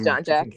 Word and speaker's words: Jonjak: Yes, it is John Jack.